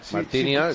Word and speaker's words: Marciniak: Sí, es un polaco de nombre impronunciable, Marciniak. – Bueno, no es tan difícil, hombre. – Bueno, sí. – Marciniak, está si bien.